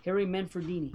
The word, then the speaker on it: Harry Manfredini: Harry Manfredini.